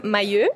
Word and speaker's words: Mailleux: So my name is Lisa Mailleux.